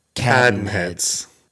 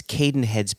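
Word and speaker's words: Cadenhead's: Jason always says Cadenheads, and Joshua says Cadenheads. Mm-hmm. As in cat, then reds.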